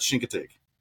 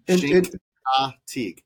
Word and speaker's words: Chincoteague: So yeah, the locals, the old school locals pronounce it Chincoteague, and they do it with their teeth clenched. It's the weirdest thing. But yeah, all the rest of us, we call it Chincoteague.